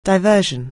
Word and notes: diversion: anglais

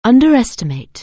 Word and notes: underestimate: anglais

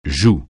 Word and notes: joue: francais

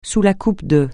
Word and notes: sous la coupe de: francais

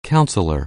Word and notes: counselor: anglais

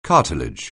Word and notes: cartilage: anglais

